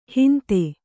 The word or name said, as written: ಹಿಂದೂ